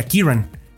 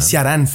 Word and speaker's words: Ciarán: porque una, una vaya pronunciación pragmática sería Kiran pero no tiene sentido en, nuestra idiosincrasia o nuestra musicalidad como latinos. Es decir, Kiran es muy ajeno. Entonces, eh, Ciarán fue la, la, pronunciación que se optó para que se sintiera no contrastara con la pronunciación de otros nombres, porque hay nombres más fáciles como André, ¿no?